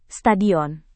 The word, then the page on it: stadion: Indonesian